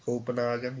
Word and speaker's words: Copenhagen: So there's Bremen or, or uh, Bremen, uh, Copenhagen. – Yeah, Copenhagen. You said it right.